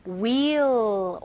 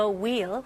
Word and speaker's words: wheel: 영어로는 wheel or steering wheel라고 합니다. Rotate the wheel. Wheel 아니에요. H는 묵음입니다.